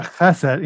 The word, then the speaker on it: chesed: And that term chesed, you got a ch when you say it, right? If you don't have some phlegm developing at the back of your throat, you're not saying it properly.